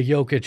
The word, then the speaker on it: Jokic: They've got Nikola Jokic, who just happens to rhyme with Joker.